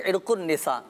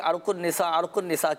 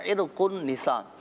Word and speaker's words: عرق النساء: عرق النساء، اچھا صحیح تلفظ عرق النساء، ہاں کیوں لوگوں میں مشہور یہ ہے کہ عرق النساء کہہ رہے ہوتے ہیں، تو صحیح تلفظ ناظرین اس کا عرق النساء،